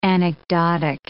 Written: anecdotic

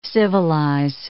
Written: civilize

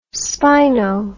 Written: spino

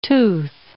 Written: tooth

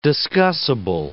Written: discussible

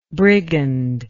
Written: brigandishly

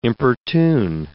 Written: importune

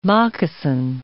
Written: moccasin

